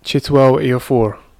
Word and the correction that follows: Chiwetel Ejiofor: Did you say his name? – Chitwell. – It's a very peculiar name. – It's a hard name to probably not a hard name, but there's a lot of vowels in the name. – No, it is a hard name, man. – How do you say that? – I'll speak for myself again. Like Chiwetel Ejiofor. Chiwetel. Yeah, he's a bad man actor.